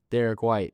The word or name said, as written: Derrick White